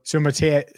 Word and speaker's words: Suamataia: Is uh, the tip from Parker, Parker Blake, who was with us last week, pronounce all the vowels in Islander names. – Sua Yeah. It's a, Sua Nope. Sumatea, Sumatea, Sumatea.